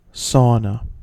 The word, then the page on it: sauna: American English